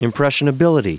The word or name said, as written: impressionability